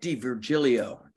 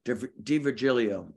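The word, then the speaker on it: D'Virgilio: So you got to go Deaver. Nick DeVirgilio. DeVirgilio.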